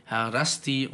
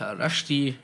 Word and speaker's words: Harasti: Ja, wie der Wein hier, der heißt nämlich Harasti und nicht Harashti und schmeckt gut.